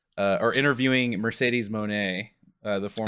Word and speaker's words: Moné: Moné.